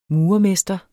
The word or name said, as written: murermester